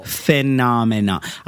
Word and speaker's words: phenomenon: Is it P-H-E-N? – N-O-M-E-N-O-N. – Oh, wow. I think I always drop a syllable. – Phenomenon. I have to think phenom. – Yeah. – Menon. – Yeah. – Except for there's not two M's.